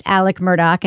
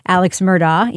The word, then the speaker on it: Alex Murdaugh: And we should mention, I think, for listeners who maybe have seen his name in headlines, it is pronounced Alec Murdaugh and not Alex Murdaugh, even though that's how it's spelled.